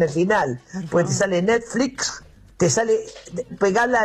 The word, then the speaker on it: Netflix: Se pronuncia Netflix, porque si decimos Netflix, te sale la S por la X, como que está al final la X.